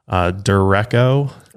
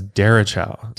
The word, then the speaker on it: derecho: And when you first look at it, it looks like it's pronounced derecho. – Or derecho. – Yeah, but it's derechow. – Derechow. – Derechow, I believe is how you pronounce it.